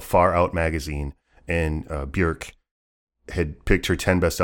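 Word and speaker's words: Björk: And it is, I will also tell you, it is Bjork. She will say it rhymes with jerk.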